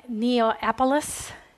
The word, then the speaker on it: Neapolis: Justin Martyr was born about 103 in Neapolis.